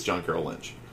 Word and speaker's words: John Carroll Lynch: I think it's John Carroll Lynch.